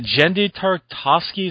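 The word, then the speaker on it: Genndy Tartakovsky: Well, I think, now, one thing to get back to this thing, because the Clone Wars, what was it, is it Jendi Tartakovsky, is that how you say it? – Yeah.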